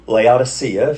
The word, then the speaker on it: Laodicea: But the cities of Colossae and Laodicea, or Laodicea, if you like that pronunciation, and Hierapolis, you got those three names?